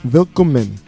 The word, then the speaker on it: Willkommen: P-A-W-C-U-T. And this track is called Willkommen. Willkommen is German for like welcome. Willkommen. But you pronounce W's like V's.